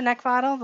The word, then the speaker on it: Neckvatal: You can find me over on LinkedIn by just typing in my name, which is Brenda Neckvatal. The last name is spelled N E C K, like the thing you want to choke. V as in Victor, A T A L always pushing out uh, lots of really interesting information out there.